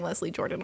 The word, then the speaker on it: Leslie Jordan: And he was going to bring Leslie Jordan on because, oh my gosh. I mean, did I say it right this time? – Yeah, you did.